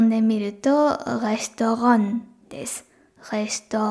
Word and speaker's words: Restaurant: レストランですね。レストラン何回も発音しちゃってください。で、最後の、えっ、と、てっていうのも発音しなくて大丈夫です。最後 N で終わっている感じですかね。